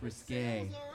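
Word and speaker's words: risqué: No, it's pronounced risque.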